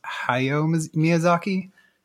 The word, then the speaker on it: Hayao Miyazaki: I think his name is pronounced Hayao Miyazaki.